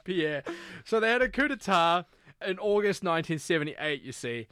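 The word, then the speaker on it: coup d'état: A coup d'etat. – A coup d'etat. – Is that how you pronounce it? – That's how you pronounce it, yeah.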